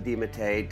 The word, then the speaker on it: DeMattei: Oh, what a privilege to have Susan DeMattei.